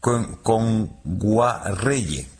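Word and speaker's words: Kngwarreye: Emily Kame Nguagierre, lo he dicho mal. – Emily Kame. – Con Guarreye. – Emily Kame con Guarreye, sí.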